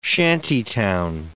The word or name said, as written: shantytown